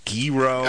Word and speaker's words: gyro: I'm pretty sure gyro. – It's a gyro. – Gyro? – Yeah. No. Sort of like a hero. – But true, with not pronouncing the H, that's not even there.